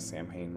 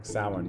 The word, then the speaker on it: Samhain: Like, that's a new perception I'm bringing into what Samhain represents. Sorry, I pronounced that wrong. Samhain represents.